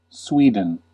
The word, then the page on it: Sweden: /ˈswi.dən/